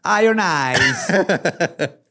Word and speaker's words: Iron Eyes: Se pronuncia Iron Eyes.